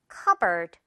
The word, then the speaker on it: cupboard: 선생님도 예전에 이 단어를 어렸을 때 처음 봤을 때는 당연히 cupboard처럼 생겨서 그런 줄 알았는데 자, 이 P 일단 묵음입니다. 그리고 뒷부분도 가볍게 처리돼서 cupboard 이렇게 처리가 된다는 거. 이거 발음이 조금 반전인 단어예요. cupboard 이렇게요. board가 아니고요.